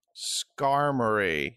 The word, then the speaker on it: Skarmory: The 227th Pokemon is Skarmory. – Skarmory. – Is that how it's like... pronounced? Fuck. – I mean, that's how I've always pronounced it.